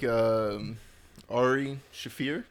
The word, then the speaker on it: Ari Shaffir: I felt like Ari Shaffir, did I pronounce it correctly this time? – That's correct.